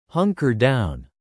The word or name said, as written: hunker down